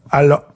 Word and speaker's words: Allo: It sounds like over to Allo, as you're pronouncing it. Is that how you're supposed to say it? – Allo? – Allo? Got you.